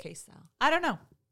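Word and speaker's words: Kacel: I will say I really appreciated that Judge Matthew Cassell, in this case, did... I think he pronounces his name Castle.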